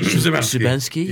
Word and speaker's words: Szymanski: Szymanski.